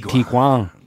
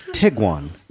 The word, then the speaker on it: Tiguan: like Tiguan. – Yeah. – Tiguan. But it's Tiguan.